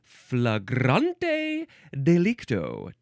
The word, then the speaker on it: flagrante delicto: This, I think, is pronounced flagrante delicto. But maybe you can say flagrante delicto.